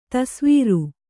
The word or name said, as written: ದೆಸೆವರಿ